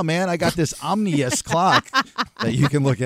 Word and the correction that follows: ominous: How's the right way to say it? – But it's... – Ominous. – Ominous.